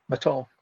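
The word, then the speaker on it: Mathon: So it's the Claire Maton or Maton? I can't remember. Is it, how do you pronounce it? – She's French, so we'll go with the short T, Maton.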